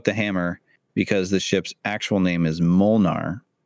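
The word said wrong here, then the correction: Mjölnir: Mjolnir? – That's the one.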